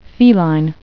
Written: feline

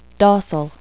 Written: docile

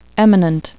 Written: eminent

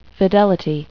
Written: fidelity

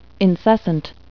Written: incessant

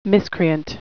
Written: miscreant

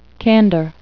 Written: candor

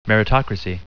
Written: meritocracy

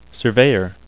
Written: surveyor

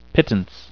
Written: pittance

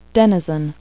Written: denizen

